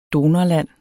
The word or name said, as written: donorland